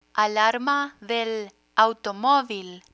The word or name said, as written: alarma del automovil